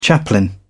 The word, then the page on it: chaplain: British English